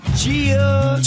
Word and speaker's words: Jeah: It's spelled J-E-A-H. If you say it like how it's spelled, it's Gia. But that's boring. No one wants to hear that. So you have to really put that emphasis on that J.